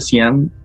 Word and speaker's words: Dzyan: Este, eh, este, libro de Cian, de hecho, se escribe D-Z-Y-A-N.